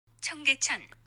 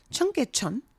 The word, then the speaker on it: Cheonggyecheon: Oh, let's listen to it. – Cheonggyecheon. – Yes. Okay. I kind of butchered that. Cheonggyecheon. Cheonggyecheon.